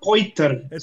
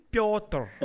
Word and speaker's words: Petr Yan: It's not Piotr. Say it again. – Piotr. – It's Piotr. No, you're digitalizing the whole channel when you say it like that.